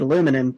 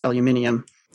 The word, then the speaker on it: aluminium: One British-Australian superiority when it comes into play, it's you used to pronounce it aluminum, but it's supposed to be pronounced aluminium.